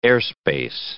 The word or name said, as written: airspace